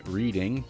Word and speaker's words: Reading: It's spelled like reading, but because you have to say everything really cool when you're from England, it's pronounced Reading, and that's in the UK.